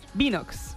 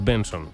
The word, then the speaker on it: BNXN: Binox. – No. – Vaya. – No es Binox ni es... Bueno, es Benson. – Benson. – No sé por qué es Benson. BNXN se pronuncia Benson.